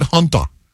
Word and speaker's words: Hanta: They spell it H-A-N, but they pronounce it Hunta. So, you know, some countries around the world, they pronounce A's different than we do. The A can be pronounced as a U.